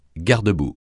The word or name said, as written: garde-boue